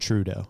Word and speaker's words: Trudeau: Is it Trudeau, is that how you say it? – Trudeau. – Trudeau.